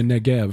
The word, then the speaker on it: Negev: And that'd be the Negev. Mm-hmm. This is one of those bees that's pronounced like a...